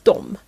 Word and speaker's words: de: Båda uttalas dem.